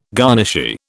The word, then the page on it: garnishee: British English